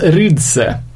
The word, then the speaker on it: Rixe: jag tror det ska uttalas Rydse. Uh, stavas R-I-X-E.